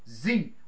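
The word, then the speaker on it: Xe: So, Halliburton, Keller Brown Root, Blackwater, now named, renamed Z, which is spelled X-E.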